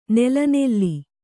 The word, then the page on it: ನೆಲೆನಿಲ್ಲು: nelenillu; Kannada